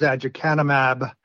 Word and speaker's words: aducanumab: I use aducanumab.